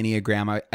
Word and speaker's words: Enneagram: And the first time I heard about the Enneagram, so it rhymes with pentagram.